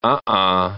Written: uh-uh

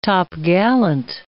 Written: topgallant

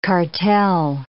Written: cartel

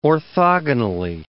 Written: orthogonally

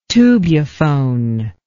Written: tubuphone

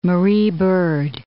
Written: Marie Byrd Land

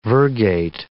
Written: virgate

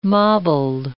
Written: mobled

